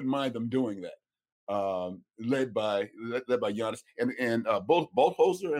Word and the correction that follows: Budenholzer: Mike Budenholzer.